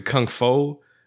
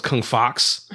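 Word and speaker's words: Kung Faux: I think I always pronounced it Kung Fo. F-A-U-X. Or as when we was kids, we didn't know French. We thought it was Kung Fox.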